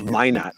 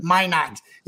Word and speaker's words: Minot: and I always pronounce this college wrong. Help me out here, Coach. – Minot. – Minot. Yeah, I was going to say Minot.